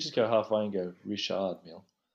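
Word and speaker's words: Richard Mille: do you pronounce it Richard Mill? – Isn't it Richard Mill? – Richard, yeah. Over there, you'd pronounce it Richard Mill.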